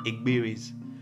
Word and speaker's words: Igbiri: They're called igbiris. – What does that mean? – Try and pronounce it. Igbiri. – Igbiri. – Okay. – That was close. – Yeah, pretty close.